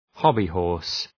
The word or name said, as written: hobby-horse